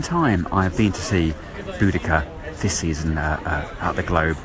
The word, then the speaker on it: Boudicca: Boudicca, Boadicea, on stage here at the Globe, it's being pronounced as Boudicca.